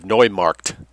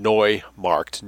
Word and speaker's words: Neumarkt: Dietrich Eckart was born on March 23rd, 1868, in the Bavarian town of Neumarkt, N-E-U. E-U is pronounced Oi in German. Neumarkt, New Market. N-E-U, M-A-R-K-T, Markt is Market.